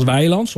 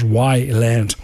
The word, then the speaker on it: Weiland: Je schrijft het als Weiland, zoals wij het hier zeggen. Maar het schijnt, je moet het uitspreken als Weiland.